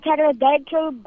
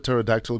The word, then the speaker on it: pterodactyl: Why did the pterodactyl go to the bathroom? Pterodactyl is that flying dinosaur. – What's coming in my head is not radio friendly. So, what's the answer? – Because the pee is silent.